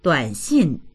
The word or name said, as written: duan3xin4